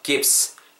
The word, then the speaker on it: gives: V 사운드는 무조건 입술 다음은 안 됩니다. 윗입, 아랫입술에 내쉬고 윗니 아랫입술에 긁으면서 gives, this gives me, this gives me 이렇게 발음해 주셔야 됩니다.